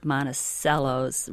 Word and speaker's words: Monticello: I know that when I lived out east and there were other Monticellos written anywhere, most people called it Monticello because that's the correct pronunciation using Italian.